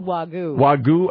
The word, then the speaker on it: Wagyu: How do you pronounce it? Wagyu? – Wagyu.